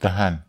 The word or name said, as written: tahan